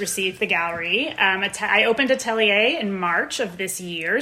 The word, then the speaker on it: atelier: Is that how you pronounce it? – Perfect pronunciation. Atelier, yes.